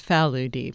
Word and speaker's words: Faludi: The Hungarian way of saying it is Faludi, but I grew up in a very Italian neighborhood, so it became Italianized, Americanized at an early age. The first thing I learned is I was mispronouncing my own last name.